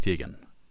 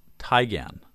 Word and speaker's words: Teigen: It says, hey boys, I've recently found out some new information about my arch nemesis Chrissy Teigen. Word is, her last name is actually pronounced Tygan, not Teigen.